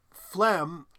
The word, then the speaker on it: phlegm: And when you split into two syllables that G is suddenly pronounced. So it's phlegm, but then it's phlegmatic.